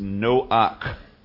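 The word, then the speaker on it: Noach (נח): By the way, the name Noah, pronounced in Hebrew, is Noach.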